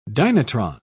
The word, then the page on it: dynatron: British English